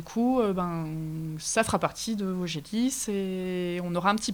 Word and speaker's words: Vosgelis: Alors une dernière petite question, ça s'appelle Vaugelis, ça s'écrit comme les Vosges, donc j'imagine c'est que le département ?